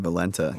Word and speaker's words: Valenta: Valenta.